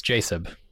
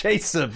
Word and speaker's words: Jacob: It's Jacob. That's how you pronounce it. – It's Jacob. Yeah, I'm sorry. Sorry, I got that wrong.